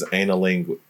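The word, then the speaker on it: anilingus: It's referred to as analingus. – Analingus. There we go.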